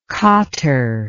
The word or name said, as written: quatre